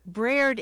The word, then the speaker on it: breard: And Braird, I guess that would be B-R-A-I-R-D, something like that? – Braird is spelled B-R-E-A-R-D, Braird.